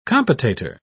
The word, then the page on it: compotator: British English